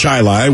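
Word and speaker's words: Chili: It's pronounced Chi-Li,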